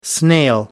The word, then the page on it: snail: American English